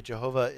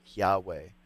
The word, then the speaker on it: Yahweh: I mean, really, Jehovah is a English, anglicized mispronunciation of the Tetragrammaton, which is, right, the four consonants of the name, which most Hebrew scholars today would all agree it should be pronounced Yahweh.